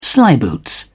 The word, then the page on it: slyboots: British English